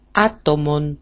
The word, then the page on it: atomon: a-tomon